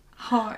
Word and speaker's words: Hoare: I think that's how you say it. H-O-A-R. Yeah. – Hoare.